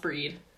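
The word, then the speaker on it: Bríd: It's spelled like bride, but it's pronounced breed.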